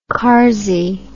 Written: carsy

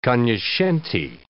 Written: cognoscenti

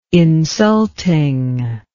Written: insulting

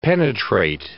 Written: penetrate

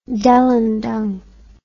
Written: delundung

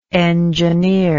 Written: engineership

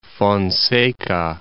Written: Fonseca, Gulf of

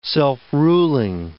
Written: self-ruling

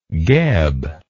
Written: geb

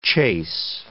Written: chase

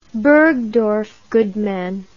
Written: bergdorf goodman

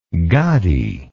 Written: gaddi

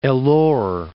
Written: Ellore

